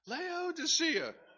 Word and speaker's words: Laodicea: Laodicea.